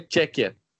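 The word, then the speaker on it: Tchéquia: Agora o, a pronúncia correta no português aqui no Brasil é Tchequia.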